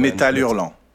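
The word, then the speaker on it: Métal Hurlant: Metal Hurlant.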